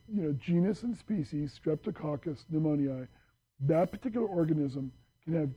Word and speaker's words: Streptococcus pneumoniae: Streptococcus pneumoniae, repeat.